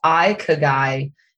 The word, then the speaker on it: Ikigai: So it's pronounced Ikigai, and it is spelled I-K-I-G-A-I.